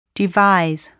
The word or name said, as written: devise